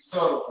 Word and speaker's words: subtle: The root word is supposed to be subtle, and I've been reaching the comments out. That subtlety, but for some strange reason, the root word. The bee is silent.